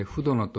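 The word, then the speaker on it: 文殿: フドノとは、文章の文、お殿様の殿を書いてフドノと読み、文書や書籍などを収めておくところを意味します。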